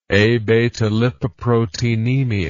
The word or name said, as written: abetalipoproteinemia